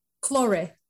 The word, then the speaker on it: Claure: Claure.